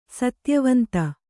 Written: ಸತ್ಯವಂತ

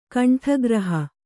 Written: ಕಂಠಗ್ರಹ

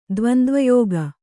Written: ದ್ವಂದ್ವಯೋಗ

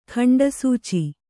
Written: ಖಂಡಸೂಚಿ

